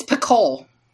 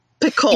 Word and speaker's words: Picoult: Is that how you say it? – Picoult. – It's Picoult. – Picoult. – You don't pronounce the T. – It's French.